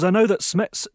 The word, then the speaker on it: SMETS: Also, he goes on to say, I'm interested in what you can tell me about SMETS 1 versus SMETS 2 meters. Am I saying that right, Stephen? – I think you are, yes.